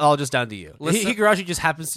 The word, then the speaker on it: Higurashi: The award goes for his constant mispronunciation of Higurashi. – It's Higurashi.